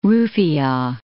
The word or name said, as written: rufiyaa